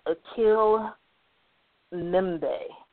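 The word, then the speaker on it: Achille Mbembe: But I believe this is pronounced Akil Mbembe, M-B-E-M-B-E. So Akil, A-C-H-I-L-L-E, Mbembe, so M-B-E-M-B-E. Yeah, I'm really bad about these names, guys, so just please forgive me.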